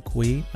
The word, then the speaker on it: Cui: And of course, you could find me, Brian Cui, my last name is spelled C U I, on LinkedIn.